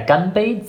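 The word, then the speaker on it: Ganbei (干杯): Ja, Ganbei sagt man immer, wenn man anstößt. Das hast du richtig ausgesprochen. Aber als ein richtiger Nordchinese sagt man Ganbei, Gan, nicht Ganbei, okay, sondern ja, sehr männlich.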